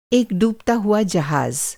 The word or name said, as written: डूबना